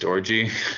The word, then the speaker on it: Orji: surname spelled O-R-J-I. – Oh. – And it's actually pronounced orgy.